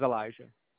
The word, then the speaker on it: Elijah: My actual name is Elijah. – Okay, how do you spell that? – E-L-I-J-A-H.